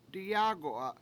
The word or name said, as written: diyAAgu7a